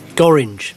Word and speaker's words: Gorringe: It's Gorringe. It's not many words that rhyme with orange, Al, but Simon Gorringe says.